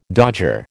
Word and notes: dodger: American English

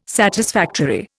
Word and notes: satisfactory: Indian

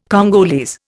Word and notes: Congolese: Indian

